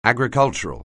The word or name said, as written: agricultural